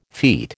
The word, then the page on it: feet: American English